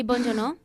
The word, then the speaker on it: Bong Joon-ho: Mejor director, Bong Joon-ho. – ¿Eh? – ¿Lo he dicho bien? – Sí, Bong Joon-ho.